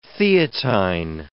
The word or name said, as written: Theatine